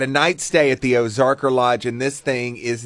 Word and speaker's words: Ozarker: Ozarker. – How do you spell that? – O-Z-A-R-K-E-R.